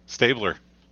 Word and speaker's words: Stabler: Stabler.